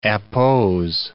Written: appose

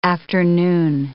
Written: afternoon

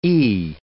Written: E

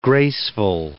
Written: graceful

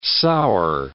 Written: sour

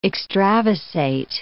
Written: extravasate

